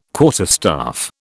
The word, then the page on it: quarterstaff: British English